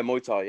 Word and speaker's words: Muay Thai: Am I saying it right? Muay Thai? – Yeah, Muay Thai, yeah.